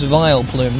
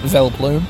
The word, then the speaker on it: Vileplume: I've been pronouncing it wrong all my life. I've just read it with an adult's eyes for the first time, this Pokemon's name. It's Vileplume, not Vileplume.